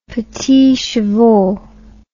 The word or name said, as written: petits chevaux